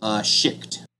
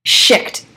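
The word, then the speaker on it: Shict: How do you pronounce, and I've been working my head around it, but I'm going to ask you anyways, how do you pronounce her species? – Schicht. – Schicht, okay.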